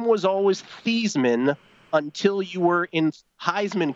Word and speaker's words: Theismann: Our public relations director, Roger Valdeseri, suggested the change of the pronunciation of my last name from Theismann to Theismann to rhyme with the trophy.